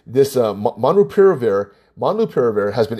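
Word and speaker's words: molnupiravir: There's a medicine from Merck called Monopiravir, Monlupiravir. Monlupiravir. Okay, I'm trying to, I kind of memorized how to say it. It's Monlupiravir.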